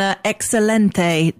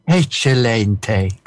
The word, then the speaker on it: excelente: Well, I hope you'll get the link if I say that it's been excelente to chat with you. – I'm sorry, I have to correct your pronunciation. It was excelente, actually, when I did it.